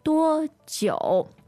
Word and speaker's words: 多久: So that's D-U-O first tone, J-I-U third tone. – Yes. 多久? Now pay attention to the pronunciation of 久. The pinyin is J-I-U. – Mm-hmm. – You might be tempted to read it as 久, but actually there's a hidden O in the pinyin I-U.